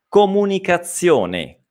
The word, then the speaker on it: comunicazione: Nuovamente una Z, questa è la stessa Z di pizza, ok? Tecnicamente si chiama Z sorda, non fa molta differenza sapere il nome, l'importante è sapere come si pronuncia, come pizza, ok? Quindi Z, zi, se, so, ok? Comunicazione, one, ok? Nuovamente la E finale si deve leggere come E e non come I, ok?